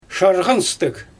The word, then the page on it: ʃarɣanstɯɣ: Xyzyl